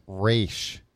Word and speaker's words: Resch: Resch. R-E-S-C-H.